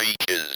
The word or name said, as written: beaches